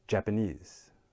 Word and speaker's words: Japanese: So which syllable has the word stress in this case? Did you say the third one this time? Good job. So here, the knees of Japanese has the stress.